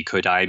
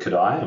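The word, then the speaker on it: Kodály: And if people aren't familiar with Kodai and would like to learn more, then it's spelled quite unusually. It's Kodaly. I think I've got that right. Kodaly, in other words.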